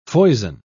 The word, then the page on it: foison: British English